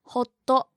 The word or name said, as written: ほっと